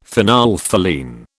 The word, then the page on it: phenolphthalein: British English